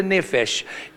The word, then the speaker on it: nephesh: It's a word called nephesh, N-E-P-H-E-S-H, and I'm not going to spell it again. You can just write it down as it phonetically, but nephesh. Now, what is the nephesh?